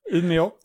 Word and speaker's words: Umeå: Is it the right pronunciation? Umeå, yeah.